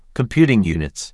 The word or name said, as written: Computing units